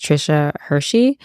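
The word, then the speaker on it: Tricia Hersey: The author, I believe her name is pronounced Trisha Hershey.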